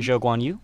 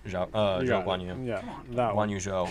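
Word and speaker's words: Zhou Guanyu: Yeah, there's some new faces as well with, I'm going to mispronounce his name, but Guan Yu Zhou? – Zhou Guan Yu? – Guan Yu Zhou, as they said on the broadcast.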